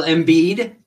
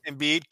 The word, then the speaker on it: Embiid: And Joel Embiid. – Embiid.